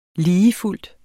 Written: ligefuldt

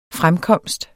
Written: fremkomst

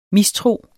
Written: mistro

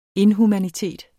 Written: inhumanitet

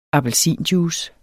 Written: appelsinjuice

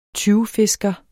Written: tyvfisker

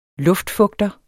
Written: luftfugter

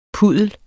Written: puddel

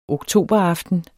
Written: oktoberaften

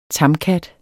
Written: tamkat

